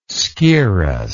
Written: scirrhus

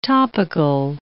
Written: topical

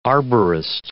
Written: arborist

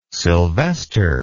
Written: silvester

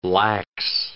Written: lax

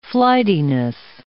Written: flightiness

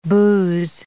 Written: booze

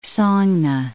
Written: Sogne Fjord

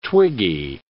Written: twiggy

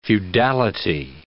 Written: feudality

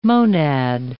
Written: monad

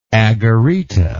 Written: agarita

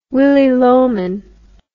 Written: Willy Loman

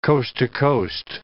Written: coast-to-coast

